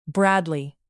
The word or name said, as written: bradley